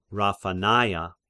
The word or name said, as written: Raphanaea